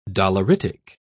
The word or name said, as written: doleritic